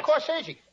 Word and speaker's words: corsage: Corsage. – That's corsage. Spelled A-G-E is pronounced like odd, as in corsage or garage.